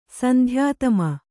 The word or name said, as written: ಸಂಧ್ಯಾತಮ